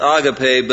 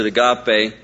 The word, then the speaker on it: agape: It's properly pronounced agape, but we all pronounce it agape. I'll probably do the same in the message. But at least we know the right way up front.